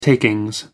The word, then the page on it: takings: American English